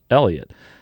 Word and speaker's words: Heliot: By the way, Elliot Ramos spelled H-E-L-I-O-T, but it is pronounced Elliot. Not Heliot, as a lot of people probably do pronounce it.